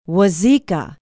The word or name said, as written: wauzeka